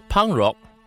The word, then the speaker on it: pangrok: The word pangrok is the local pronunciation of punk rock, and sulap is a hut or a resting place usually used by the farmers in Sabah, Borneo.